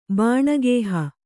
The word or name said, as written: ಬನಗಾಹು